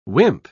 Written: wimp

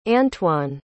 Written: antwain

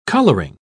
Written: coloring

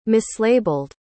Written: mislabeled

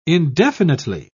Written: indefinitely

